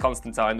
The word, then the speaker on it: Constantine: and it is pronounced Constantine, don't tell me it's not, I'm from Liverpool,